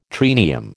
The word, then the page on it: triennium: American English